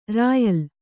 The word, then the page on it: ರಾಯಲ್: rāyal